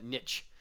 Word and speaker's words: niche: Look, it makes sense to say niche, like quiche.